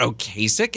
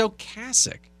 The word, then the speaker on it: Ocasek: His name's not Ocasek. It's Ocasek. Yeah.